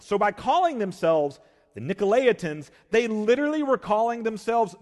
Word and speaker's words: Nicolaitans: I will probably call them the Nicolaitans. I've said that my whole life. That's not how you say it. It's Nicolaitans.